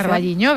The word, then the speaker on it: O Carballiño: O Carballiño, no lo sé pronunciar. – Carballiño, bien, exacto.